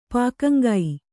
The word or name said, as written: ಪಾಕಂಗೈ